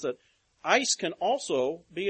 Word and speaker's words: eis: It is the Greek word eis, E-I-S, is how it's spelled.